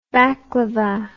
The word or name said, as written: baclava